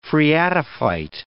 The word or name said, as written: phreatophyte